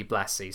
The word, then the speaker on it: Blassie: One of the first wrestlers to embrace this style was Classy Freddie Blassie. I should pronounce it that way, shouldn't I? Because it was supposed to rhyme.